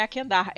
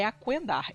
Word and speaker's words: Aquendar: E ela corrigiu a minha pronúncia, não é Aquendar, é Aquendar. Eu acho,